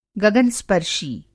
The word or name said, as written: गगन-स्पर्शी